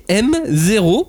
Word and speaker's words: MX0: Donc, on vous parle d'un manga nommé M0, mais qui s'écrit MX0, mais qu'on dit M0.